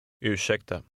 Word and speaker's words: Ursäkta: Ursäkta. – This word contains sounds that don't exist exactly in English. Repeat it after the speaker, trying to make your pronunciation sound like his.